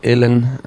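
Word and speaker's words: uilleann: It's U-I-L-L-E-A-N-N, and it's pronounced Illan, and Illan is the Irish for elbow,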